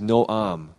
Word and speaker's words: Noam: And the Hebrew word, no idea how to pronounce it, but it's N-O-A-M, Noam.